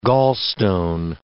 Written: gallstone

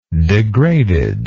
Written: degradedness

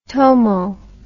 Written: tomo

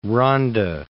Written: Rhondda

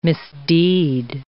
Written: misdeed

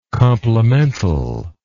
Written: complementally

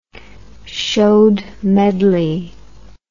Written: chaudmedley

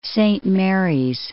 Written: Saint Marys

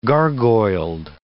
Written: gargoyled

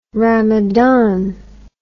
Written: rhamadhan

